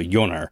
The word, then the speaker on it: Joner: The main character, or the main hero in both of these, is an actor by the name of Christopher Joner, or Joner, but it's a J, so it's a soft J in Norwegian, right. So Joner.